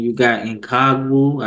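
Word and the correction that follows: Ngakoue: I think that's his name. I probably butchered it. I'm sorry. But Ngakwe, I know he was really – what's his name? – I think it's Ngakwe. – Ngakwe, there we go, there we go.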